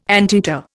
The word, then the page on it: anteater: Indian